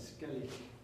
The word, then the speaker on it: Skellig: Je hebt een stuk gemaakt dat heet Skellig Monks. Mm-hmm. Hoe ik dat goed uitspreken? – Skellig. – Skellig. Yes.